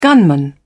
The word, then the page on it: gunman: British English